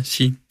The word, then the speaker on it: Xi: Xi doesn't look... Er det rigtigt udtalt? – Ja, Xi.